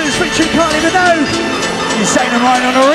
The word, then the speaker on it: Minogue: It's Kylie Minogue.